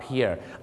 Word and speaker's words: pier: P I E R เพียร์แล้วออกเสียงว่าเพียนะครับบางคนนึกว่าปีแอร์นั่นมันชื่อคนฝรั่งเศส